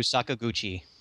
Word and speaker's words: Sakaguchi: Sakaguchi.